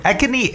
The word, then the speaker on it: acne: 说到青春痘这个词呢，其实有很很多种说法，一个比较具有代表性的叫 acne，acne，that's a c n e，这个单词的读音叫做 acne，so acne is a uh, skin condition common among young people that produces many pimples.